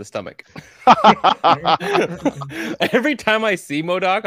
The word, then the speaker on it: MODOK: Sorry, not Madoc. I keep Thank you, Darcy. I heard you. It slowly got through my brain. I'm like, oh, wait, I said that wrong.